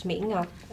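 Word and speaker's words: Mỹ Ngọc: Hi, my name is Mi Ngoc. In Vietnamese, it's pronounced Mi Ngoc, and it means beautiful pearl.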